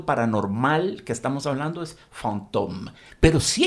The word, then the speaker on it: fantôme: Es muy interesante que la palabra fantasma en francés se dice fantôme.